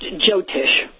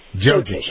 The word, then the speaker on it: Jyotish: like Jyotish, am I even pronouncing it? J-Y-O-T-I-S-H? How do you pronounce that? – Well, that's actually pronounced Jyotish. – Jyotish.